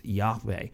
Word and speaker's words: Yahweh: Well, it's actually a Hebrew word that we can't quite pronounce, but we think it's pronounced Yahweh.